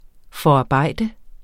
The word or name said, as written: forarbejde